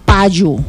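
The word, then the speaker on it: payú: El pañuelo se dice payu y se escribe de esta manera. Empezamos con la letra P. – P. – A. – A. – Acento en la A, en este caso lleva un acento.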